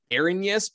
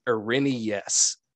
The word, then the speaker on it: Irenaeus: I used to call it Irenaeus, but apparently the Greek pronunciation is Irenaeus.